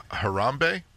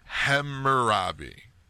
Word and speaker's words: Hammurabi: The ancient code of Harambe? No. Hammurabi. Yeah. Hemorrhoid? I'm pronouncing it wrong.